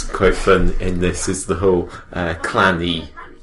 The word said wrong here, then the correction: Cloney: Cloney. Cloney. Sorry, my my pronunciation, Cloney.